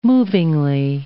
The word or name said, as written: movingly